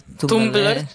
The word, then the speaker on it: Tumblr: Es que yo nunca sé cómo pronunciar Trumbler. – Tumblr. Tumblr. Un Tumblr. – Ah, pues mira, he dicho una R de más.